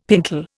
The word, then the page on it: pintle: Indian